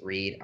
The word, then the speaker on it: Reid: You can follow me by following my Instagram, which is The Real Reed Miller, and that's Reed, R-E-I-D, not R-E-A-D.